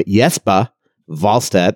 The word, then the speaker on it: Jesper Wallstedt: We will call him Jespa Wahlstedt. That's the way it's pronounced in Sweden.